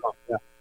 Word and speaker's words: lagom: Lagom? Am I saying that right? – Lagom, yeah. Actually, the word lagom comes from the whole team.